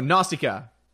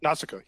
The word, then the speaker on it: Nausicaa: Escacha or Natasha of the Valley of the Wind. – Oh, Nausicaa. – Nausicaa. Sorry.